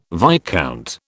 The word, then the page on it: viscount: British English